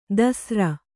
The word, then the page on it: ದಸ್ರ: dasra